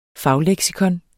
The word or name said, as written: fagleksikon